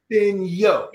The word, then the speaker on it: Fenyo: Finoy. No, God, no. She just told me. Dad gummit. Say your last name. – Fenyo, like Fenyo. – Fenyo. – Yo, like the E is completely silent.